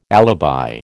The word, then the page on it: alibi: American English